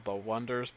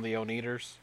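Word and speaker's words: The Oneders: Because they got that band, they're called The Wonders, but they spell it O-N-E, like one, yes. And then yes. Everyone's calling them The Oneaters.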